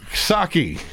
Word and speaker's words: Psaki: Jen Psaki, where the P is silent.